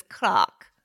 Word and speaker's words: clerk: Clock, they keep saying. – I'm sorry? – The way they pronounce it is clock.